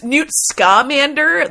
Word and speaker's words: Scamander: You said, as Newt's I was saying, is it pronounced that way, Newt's Commander, though? – Buddy, I've only read it. It's Newt Scamander, the Ska Commander.